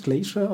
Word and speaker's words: Glacier: Von der LP Doomsday Glacier, wird es Glacier ausgesprochen? – Yes.